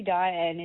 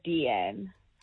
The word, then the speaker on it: Deanne: Well, people tend to always call me Diane instead of Deanne. So they don't realize that there's an E in my name, so it's D-N, not Diane.